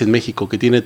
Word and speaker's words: México: O puedes decir México, que ahí sería como J.